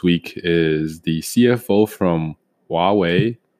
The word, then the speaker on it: Huawei: So I think the proper pronunciation is Huawei.